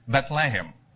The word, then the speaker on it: Bethlehem: It's like in the word lechaim, you know, which we all know, to life, or the other word in which that H is kind of silent in English, but it's very well pronounced in Hebrew, is Bethlehem. So in Bethlehem, we don't really say that H, and lechem is really the word for bread.